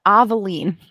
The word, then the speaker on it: Avaline: So, it says on there actually how to pronounce it. Avaline. So I was wrong all the way around.